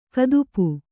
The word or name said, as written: ಗದಪು